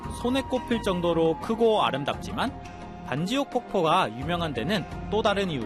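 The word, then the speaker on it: Bản Giốc: 이게 영어 발음으로 반지옥이지 베트남어로는 만접이라고 하는 곳이에요.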